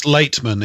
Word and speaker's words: Laitman: Michael, Dr. Michael, and this is the Kabbalist name, Latiman, L-A-I-T-I-M-A-N. I don't know if you've got that in front of you. – Latiman. It's Latiman. It's pronounced L-A-I-T.